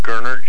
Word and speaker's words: Gerner: Chris, T-H-R-I-S. – Uh-huh. – Gerner, G-E-R-N-E-R. – G-E-R-N-E-R.